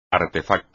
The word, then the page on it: artefacto: espagnol